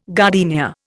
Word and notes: gardenia: Indian

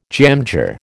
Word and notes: jamjar: American English

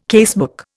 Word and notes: casebook: Indian

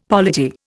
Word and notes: polity: Indian

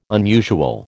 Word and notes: unusual: American English

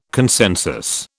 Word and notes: consensus: British English

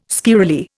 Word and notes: squirrelly: Indian

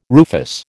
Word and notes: rufous: American English

